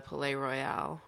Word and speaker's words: Palais Royal: I'm sure you saw with the, they basically recreated a supermarket in the Palais Royal. Or is that how you pronounce it? – Yeah, I think that's it.